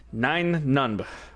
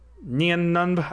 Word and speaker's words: Nien Nunb: Nien Nunb? I'm pretty sure it's Nine Nunb. But you pronounce it Nien Nunb. We're all entitled to our own pronunciations. I say Nine Nunb because there's a 10 Nunb.